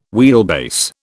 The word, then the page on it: wheelbase: British English